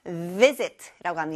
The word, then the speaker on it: visit: 이렇게 방문하고 찾아가는 것은 visit라고 합니다. 여기 V 발음이 또 나왔네요. 따라해보세요. visit, visit, visit.